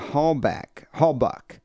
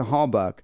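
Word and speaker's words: Halbach: During the time of the interview, Teresa Hallbuck, that's how you say her name, Teresa Hallbuck, was listed as missing.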